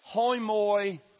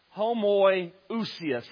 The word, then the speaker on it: homoiousios: So if you have the iota, it's pronounced hoimoiousios. And that means of a similar nature. Christ is like God. Homoiousios.